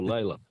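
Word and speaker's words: Lalor: Or Layla. Lawler is how it's supposed to be pronounced, but Layla's the other way.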